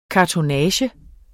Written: kartonnage

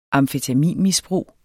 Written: amfetaminmisbrug

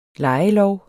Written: lejelov

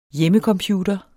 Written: hjemmecomputer

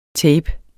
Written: tapet

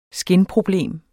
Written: skinproblem

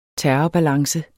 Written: terrorbalance

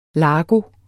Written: largo

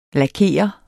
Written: Lakerer

